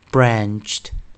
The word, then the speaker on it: branched: 那我们在 anch 这一组啊，呃，韵音的时候加 ed 是直接加，好，那当然 ed 还是念 t，因为前面是二合辅音 ch，所以我们呢这个生词的发音为 branched, branched。